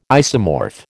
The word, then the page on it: isomorph: American English